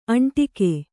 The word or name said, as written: ಅಂಟಿಕ್ಕು